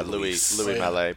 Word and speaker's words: Louis Malle: Pronounce it instead of Louis Malle, probably Luis Malle. – Probably Louis. – Louis? – Yeah, Louis Malle. Probably. – Oh, yeah, not Luis guy.